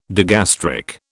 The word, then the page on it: digastric: British English